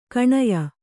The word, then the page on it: ಕಣಯ: kaṇaya